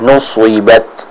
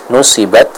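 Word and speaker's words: nuswibat (نُصِبَتْ): Contohnya kita baca nuswibat, nuswibat. Ya, jadi bukan nusi. Kalau kita baca dengan tipis nusibat, nanti jatuhnya akan seperti huruf sin. Namun di sini kita harus membacanya dengan ketebalan yang sempurna sehingga apa? Sehingga huruf shad itu benar-benar terdengar dengan bacaan yang jelas, tidak tertukar suaranya dengan huruf sin.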